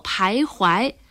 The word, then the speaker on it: 徘徊: 带双人旁的不读回，得读作徘徊。